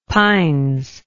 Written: pines

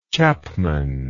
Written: chapmanship

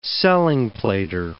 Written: selling-plater

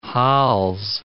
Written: hols

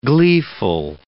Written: gleeful

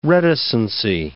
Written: reticency

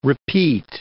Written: repeat

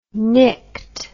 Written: nyct

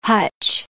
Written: hutch

